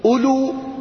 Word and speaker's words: أولو: التي هي منصوبة ألو لكي لا تشتبه بإلى جيء بالواو. أولي هي في الكتابة مكتوبة لكن في النطق ما تنطق.